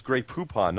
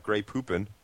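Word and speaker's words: Grey Poupon: It's gray poupon, not gray poopin.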